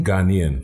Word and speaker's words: Ghanaian: What are some of the kind of hallmarks of Ghanaian? – Ghanaian. – Ghanaian culture. – Yeah. – There it is with that syllable.